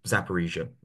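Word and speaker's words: Zaporizhzhia: So you're from Zaporizhia originally. – You pronounced it very well. I'm impressed.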